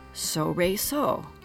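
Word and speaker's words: Solresol: It's now called so, re, so. And it's spelled like the solfège syllables, so, re, and so.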